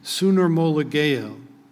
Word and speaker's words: sunarmologeo: The word "joined" there is translated from the Greek word sunarmologeo. I'll spell that. S-U-N-A-R-M-O-L-O-G-E-O.